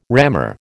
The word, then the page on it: rammer: American English